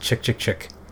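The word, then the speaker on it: Chk Chk Chk: Yeah, I think they pronounce it Chick Chick Chick.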